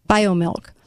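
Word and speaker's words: Biomilq: But it's interesting because they're introducing this new product called BioMilk, but it's spelled M-I-L-Q, BioMilk.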